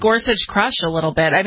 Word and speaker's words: Gorsuch: Is that how he pronounces his name? It's Gorsuch, right? – Gorsuch, yeah. Yeah.